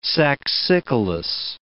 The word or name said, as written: saxicolous